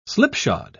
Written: slipshod

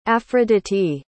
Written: afroditi

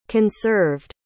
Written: conserved

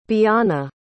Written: biana

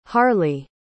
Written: harleigh

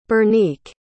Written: Bernique

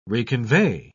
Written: reconvey

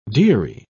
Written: deary

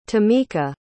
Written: tomika

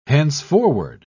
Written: henceforward

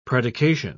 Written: predication

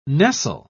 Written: nestle

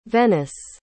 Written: venice

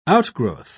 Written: outgrowth

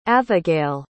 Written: avigail

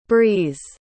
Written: brees

